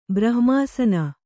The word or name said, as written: ಬ್ರಹ್ಮಾಸನ